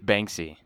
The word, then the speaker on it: Banksy: Did you say Banks? Banksy? – Banksy. – Banksy. – I don't know too much about him. I just know he's a mysterious artist. – Can you spell it? – I think it's just like B-A-N-K-S-Y. – Banksy. – Oh, okay. So it's one word. It's not like bank and then a letter C.